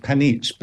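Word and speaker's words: Panić: I think you pronounce it panich, but it's spelt panic.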